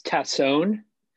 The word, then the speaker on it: Tassone: Yeah, it's Tassone.